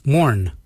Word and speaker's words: Moorn: Yourned is spelled Y-O-O-R-N-D. And the last color is Mourn. Mourn is black. Mourn is spelled M-O-O-R-N.